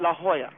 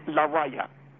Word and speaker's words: La Jolla: They call it La Jolla. It's La Raya.